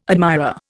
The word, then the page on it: admirer: Indian